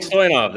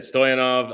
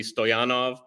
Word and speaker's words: Stoyanov: Mike Stoyanov. That's it. Stoyanov. I guess if we were in Ukraine or Bulgaria, it would be Stoyanov, but we're not.